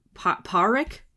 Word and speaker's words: Podrick: But they would never pronounce the D. – Yeah. – I know it looked like it's Podrick, but it sounded like they would never say the D.